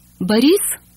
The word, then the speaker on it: Boris: Boris, Boris, Boris is stressed on the second syllable. And note that the O in the unstressed first syllable becomes an A.